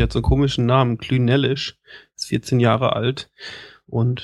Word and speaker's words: Clynelish: Fortan soll er nur noch Klünellisch ausgesprochen werden. Das klingt super. Doch, Klünellisch. – Ja, der wird komisch. Also der wird C-L-Y-N-E-L-I-S-H.